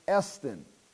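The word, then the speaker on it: estin (ἐστίν): And this next word right here is pronounced estin. Epsilon, sigma, tau, iota, nu.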